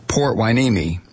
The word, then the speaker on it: Port Hueneme: So I mispronounced the naval base in Ventura, California. Apparently it is pronounced Port Hueneme.